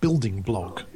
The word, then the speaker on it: BLDGBLG: There's a wonderful blog which I nick loads of stuff from called Building Blog, but it's spelled BLDGBLG, Building Blog, which is one.